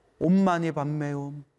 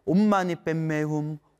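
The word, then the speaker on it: 옴마니 반메훔: 옴마니 반메옴, 옴마니 반메옴, 옴마니 반메옴. 티베트 발음으로는 옴마니 뱀메훔, 옴마니 뱀메훔, 옴마니 뱀메훔.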